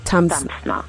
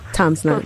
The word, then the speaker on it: Tamsna: Tamsna. – Tamsna.